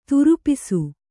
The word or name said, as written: ದ್ರಪ್ಸ